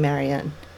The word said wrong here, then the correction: Marianne: And she was like, no, it's Marianne. You're saying it wrong.